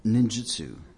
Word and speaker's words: ninjutsu: Well, the art would be called ninjutsu. So, NINJUTSU, ninjutsu. Sometimes you'll see it spelled with an I, ninjutsu, JITSU. That's generally a flag that whoever's purporting that is fraudulent.